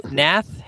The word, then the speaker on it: Hnath: Yeah, how do you pronounce that name? – Nath? – I bet the H is silent. Yeah, yeah. – H-N-A-T-H is the writer.